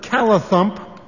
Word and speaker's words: callithump: We have had a few moments with the word callithump. We will go first. – I think it's actually pronounced call it hump.